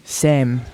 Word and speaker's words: Sam: Like, so for me, wait, say my name, bro. S-A-M. – Oh, Sam, right, sorry. – Wait, say it again. – Sam. – No, it's Sam. – Sam.